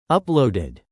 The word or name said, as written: uploaded